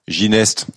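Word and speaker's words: Castelginest: C'est Castelginet ou Gineste? – Gineste. Vous savez, Gineste. Ici, on prononce toutes les lettres.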